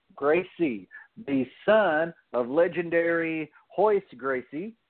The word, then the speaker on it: Royce: I was not aware that in Brazil, your R's are pronounced like H's. So everybody probably calls him Royce Gracie. But when you get down into the technicalities of how the Brazilians do things, it is pronounced Hoist Gracie.